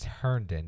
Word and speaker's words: Herndon: Tight end, Christopher Hendon. I think that's how you pronounce it. – I can't help you. I got it pulled up. So. – Oh, man. It's Herndon. It's an R. I didn't realize that.